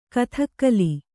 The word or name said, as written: ಗುದಿಗಾಲು